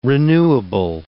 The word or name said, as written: renewable